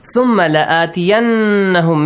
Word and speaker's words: ثُمَّ لَآتِيَنَّهُمْ: ثُمَّ لَآتِيَنَّهُمْ Make sure ميم مشدد نون مشدد. You have to pronounce it. – With a nasal sound. – A nasal sound. Eh? – Stretch it for two counts.